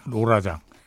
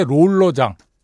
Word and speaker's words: 롤러장: 이게 사실 원 오리지널은 로라장이에요. 예. 로라장. 아, 이게 일본식으로 발음하는 거죠. 이게 받침이 없잖아요. 일본 친구들은.